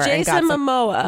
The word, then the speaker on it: Jason Momoa: I think it's Momoa. – Wait, I did a review on this. – I said it wrong on a podcast before. – Jason Momoa. – Momoa. Okay, yeah.